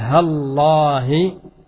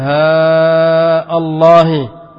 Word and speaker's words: هالله: يعني تقول هالله بجعل الألف مقصورة، وألف لفظ الجلالة همزة وصل، ويجوز أن تمد مع قطع همزة ماذا؟ لفظ الجلاء فتقول ها الله ها الله لأفعلن، ويجوز أن تمد وتجعل اللام ماذا الهمزة وصل ها الله.